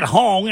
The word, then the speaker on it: Hong: And I said Hong, and then rhymes with ding-dong.